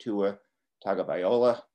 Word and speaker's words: Tua Tagovailoa: more recently Tua Tagovailoa. How's my pronunciation there? They just that was very good.